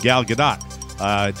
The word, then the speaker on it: Gal Gadot: Alleged sexual harasser Brett Ratner was a producer on Wonder Woman, and that's not cool with Gal Gadot.